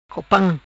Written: Copam

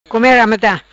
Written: Come Rameta

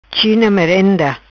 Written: China merenda

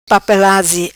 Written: Papelazi

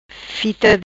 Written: Fita

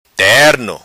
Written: Terno

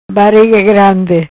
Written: Bariga grandi

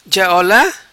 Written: Ja Ola

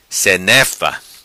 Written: Senefa

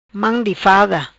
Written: Mam di fada

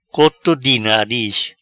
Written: Coto Di Nariz

